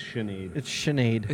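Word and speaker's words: Sinéad: Okay, could you say her name again? – Sinead O'Connor. – It's Sinead. – Is it Sinead? – Yeah.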